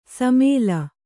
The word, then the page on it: ಶಮಲ: śamala